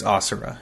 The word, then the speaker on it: Asura: Do they pronounce it Asura? – It's Asura in the game.